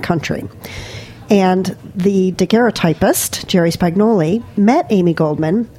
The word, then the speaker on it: daguerreotypist: It's illustrated by a daguerreotypist. Is that how you say it? – Daguerreotypist. – Daguerreotypist. Yeah, exactly.